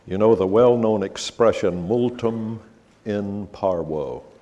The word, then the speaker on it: multum in parvo: That's the way it's properly pronounced in Latin. Multum in parvo, much in little. And that's exactly what Hosea is, a multum in parvo. V in Latin was always pronounced like a W in English.